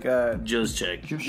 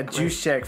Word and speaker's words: Juszczyk: It's like Juszczyk. – Yeah, Juszczyk for 5 years for $27 million.